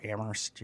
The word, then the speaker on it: Amherst: You know the cliche about Amherst, you know, it's the town where only the H is silent.